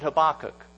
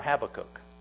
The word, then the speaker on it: Habakkuk: And today, and Lord willing, next week, we'd like to look at Habakkuk. Now, at the beginning, you can pronounce it Habakkuk or Habakkuk. I don't care. I'm saying Habakkuk.